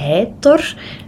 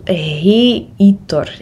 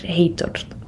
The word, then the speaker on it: Heitor: Ja, op zijn Nederlands zouden we zeggen heitor. Maar ik weet dat ze in eh, het Portugees de klinkers apart uitspreken. Dus het is zo heitor, heitor. Op die manier uitgesproken.